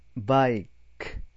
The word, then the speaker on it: bike: だから、ね、読み方は ES で終わってるんだけども、ここでは、ね、バイク、ク、ク。ブックと同じだよね。クで終わってるじゃない。だから、スだよね。ね。バイクの読み方はいいかな。最後に E があるから、ね。この I は I って読むんだよ。アルファベット読みするんだよ。